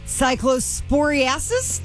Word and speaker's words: cyclosporiasis: Health officials are warning people to take caution with fresh produce as an outbreak of cyclosporiasis.